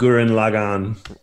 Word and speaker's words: Gurren Lagann: Is there a consensus? – Yeah, it's Ithaca 7 and Gurren Lagann.